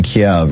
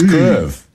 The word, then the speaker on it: Kyiv: And I will try and pronounce it now, and I will get it wrong, but it is basically So it's Kyiv. – It is a two-syllable word.